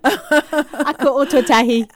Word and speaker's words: Ako Ōtautahi: Ako Ōtautahi.